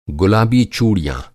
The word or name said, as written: गुलाबी